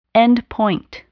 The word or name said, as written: endpoint